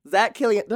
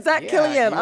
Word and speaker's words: Zach Kilian: We have the incredible wig extraordinaire, Zach Kilian. Did I say his name right? – You did. – I did, Zach Kilian.